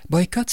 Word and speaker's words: Boikutt: Boycott, ça s'écrit B-O-I-K-U-T-T. Asife, c'est A-S-I-F-E-H.